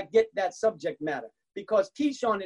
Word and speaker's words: Kishon: The Bible says that after defeating the false prophets of Baal, that they buried the dead bodies at a brook called Kishon. That is the exact Hebrew pronunciation, Kishon.